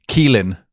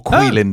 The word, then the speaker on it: Caoilfhionn: This name is C-A-O-I-L F-H-I-O-N-N. – What? – Yes. – It is pronounced, Boydy, Keelin or Quilin, depending on the dialect.